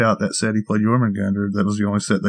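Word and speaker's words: Jörmungandr: Can I just tell y'all that I love me some Jormungandr? Did I say it right? That's close.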